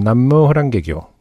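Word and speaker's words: 남묘 호랑개교 (南無妙法蓮華経): 남묘 호랭개교 그쵸? 음. – 어, 이게 좀그 주석 남묘 호랭개교라고 불림 가로 열고 나무 묘법 연화경의 일본식 발음 남묘 호랑개교 개켜.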